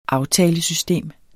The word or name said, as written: aftalesystem